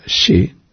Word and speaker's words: che: En el guaraní, por ejemplo, está la palabra que se dice, que se pronuncia she, se escribe C-H-E. El hombre de Buenos Aires pronuncia che.